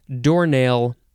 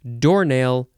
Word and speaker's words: doornail: Doornail is next. You can say, you can emphasize either syllable. Doornail, doornail. Noun from the 14th century, a large-headed nail.